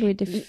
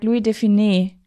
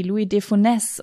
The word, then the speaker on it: Louis de Funès: Der hat meine ganze Kindheit lang, meine ganze Familie hat immer gesagt Louis de Funès. Und dann irgendwann habe ich gelernt, das heißt gar nicht so, das wird viel viel härter ausgesprochen irgendwie, Louis de Funès oder so.